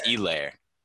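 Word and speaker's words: Edwards-Helaire: He didn't pronounce the H, which is good. I know that's right. – Helaire.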